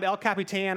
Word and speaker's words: El Capitan: Or I was watching this free climber climb El Capitan, or how you say it, in Yosemite.